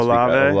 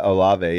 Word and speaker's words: Olave: I haven't watched him yet this Olave. – Week. Olave, yeah.